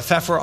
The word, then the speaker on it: Pfeffer: Thank you so much, Robin, for being on the Pfeffer on Power podcast. We are available on Spotify and Apple. Follow me on LinkedIn. My last name is spelled P-F-E-F-F-E-R, which is a nice Austrian way of saying pepper.